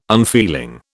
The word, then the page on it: unfeeling: British English